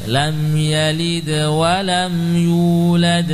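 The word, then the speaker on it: Lam yalid wa lam yulad (لم يولد): tidak ada perbedaan. Lam yalid wa lam yulad. – Lam yalid wa lam yulad. – Bukan yulad. Jangan sampai pindah ke makhraj tenggorokan hamzah. Yulad keluar.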